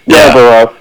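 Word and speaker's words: Yarbrough: There's a lot of Yarborough. – Is that how you say it? – Yarborough. – Yeah.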